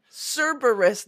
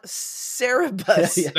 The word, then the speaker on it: Cerberus: You have both answered correctly, which is Cerberus. Yes. – Oh, I spelled it wrong. Oh, it's Cerberus, not Cerebus. – That is a unrelated comic book.